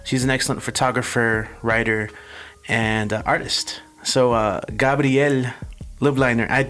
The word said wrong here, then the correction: Lubliner: It's Lubliner, but it's really hard to say. – Okay. – Lubliner. Yeah. – Lubliner.